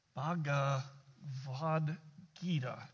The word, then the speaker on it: Bhagavad Gita: Bhagavad Gita is how you say that.